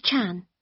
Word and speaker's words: Chan: No, it's T-H-O-M-S-O-N. – Okay. – Number six. – What name is it? – Jenny Chan. – Can you spell that, please? – Yes. J-E-N-N-Y-C-H-A-N.